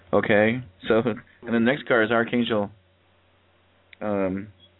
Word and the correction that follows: Chamuel: I'm trying to remember how the last lady actually pronounced that. Chamuel. Thank you.